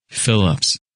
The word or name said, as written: Phillips